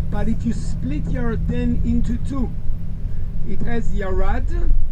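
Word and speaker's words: Yarden (ירדן): How do you pronounce Jordan in Hebrew? – Yarden. – Yarden, which is also a name of a good wine.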